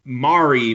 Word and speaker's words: Mari: So her name is Anna, but it's A-N-A. And then it's M-A-R-I. It's pronounced like calamari. Mari.